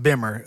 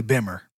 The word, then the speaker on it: Bimmer: It's actually pronounced Bimmer. Us snobs, we don't... Beamer is something, you know what I'm saying, poor people say. It's actually Bimmer.